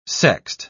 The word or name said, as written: sext